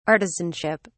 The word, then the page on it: artisanship: British English